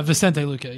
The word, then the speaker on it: Vicente Luque: Vincent Luque, my pronouncing Barberena. – Vicente Luque, yeah.